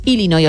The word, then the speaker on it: Illinois: Pero es Illinois, o sea, si tú lo pronuncias bien y tienes buena dicción, es Illinois. – Illinois. – Luego, si lo pronuncias rápido, según el acento, como decía Beto también, pues te sale una medio E, pero nunca un Illinois.